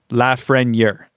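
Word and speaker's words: Lafrenière: McKenzie had a spiel saying it's Alexi Lafreniere, and you pronounce the R.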